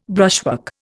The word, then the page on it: brushwork: Indian